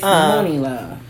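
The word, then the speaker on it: Muni Long: Mooney Love. It's spelled M-U-N-I. – Okay, because I'm thinking of Mooney Love, so that's why I'm getting it wrong. – Yeah, it's Mooney Love.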